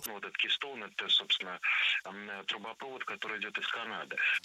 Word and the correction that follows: трубопровод: Не трубопровод, а трубопровод. В сложных словах со второй частью «провод» ударение падает на последний слог.